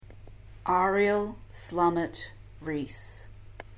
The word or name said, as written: Ariel Slamet Ries